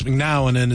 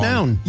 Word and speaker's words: McNown: Max McNown is in the studio. How do you say your last name? I always say it McNown. – Wrong. – McNown. – You know? I always say, because it's M-C-N-O-W-N, McNown.